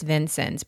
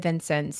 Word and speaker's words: Vincennes: So we think we're pretty confident it's Vincennes, but if you Google it, you will get four different pronunciations. So we went to the source, to the people, and they said Vincennes.